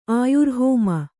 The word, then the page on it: ಆಯುರ್ಹೋಮ: Kannada